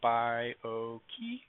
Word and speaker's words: Bi-O-Key: It's got three syllables. I say it as Bi-O-Key, and just giving you some phonetic way of remembering how to say it.